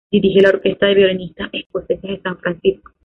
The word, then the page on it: Francisco: ispanyolca